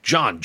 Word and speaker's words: Jon: John, J-O-N.